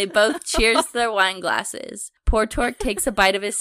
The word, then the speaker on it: Portork: Portork? – Portork. – How do you spell it? – P-O-R-T-O-R-K.